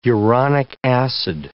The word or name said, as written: uronic acid